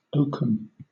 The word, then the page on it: oakum: British English